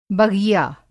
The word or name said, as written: बग़िया